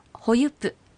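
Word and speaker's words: ホユプ: ほゆぷ、はい、この最後のぷですけどちょっと弱く発音がなりがちなのではっきりと発音してください。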